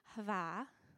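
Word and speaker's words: hava (אהבה): that the aleph is actually a silent letter. So actually when we say ahava, and I say it with a horrible Michigan accent, sorry. But it's actually a silent letter. So how it's really pronounced is hava, right? You breathe in. You're actually breathing in that first letter, hava.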